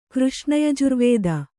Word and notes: ಕೃಷ್ಣಯಜುರ್ವೇದ: kř'hṇa yajurvēda

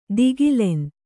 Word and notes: ಧಿಗಿಲನೆ: Kannada

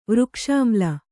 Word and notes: ವೃಕ್ಷಾಮ್ಲ: vřkṣāmla

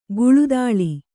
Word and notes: ಕಳ್ಳದಾಳ: kaḷḷadāḷa

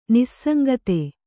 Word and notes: ನಿಸ್ಸಂಗತೆ: Kannada

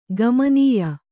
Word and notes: ಗಮನೀಯ: Kannada

